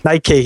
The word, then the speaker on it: Nike: Sorry, how do you pronounce that? I forgot. There's a different way. – Nike. – Yeah, Nike. Sorry.